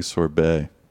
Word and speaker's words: sorbate: So like, I keep thinking you're saying sorbet. – Are you just saying sorbet and pronouncing the G? – Yeah, you're saying it weird. – No, it's a different word. It's with an A. Sorbate. B-A-T-E.